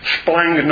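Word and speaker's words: splangnon (σπλάγχνον): The almost unpronounceable Greek word is that's translated into the English bowels, get this one, is splangnon. I'm going to spell it for you because you write it down and see if you can pronounce it. SPLANGKHNON. You're four consonants together there.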